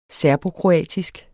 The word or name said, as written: serbokroatisk